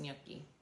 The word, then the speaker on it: gnocchi: It is pronounced gnocchi. The G is silent.